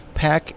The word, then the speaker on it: PAQ: And with me, I have Quentin Rund with the company PAC Interactive. And PAC is spelled P-A-Q, just so you know.